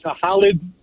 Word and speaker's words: Halid: His name was Halid. That's how you pronounce it. But, he's like a country singer in our country. – Really? His name was Halib? – Yeah. Halid. It's like H-A-L-I-D.